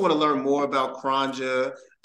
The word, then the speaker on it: Cronja: That's C-R-O-N-J-A Culture. And a lot of times too, you know, it is, that's how you pronounce Kronja. And just to make it easier, you just put the two words together, chronic and ganja, just to make it easy for everyone.